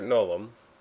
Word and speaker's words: Gnolaum: Can you spell that for with a silent G. – Okay. Yes. – As complicated as it can be. – G-N-O-L-A-U-M. – Okay. Actually, before you go on, so you're Nolum.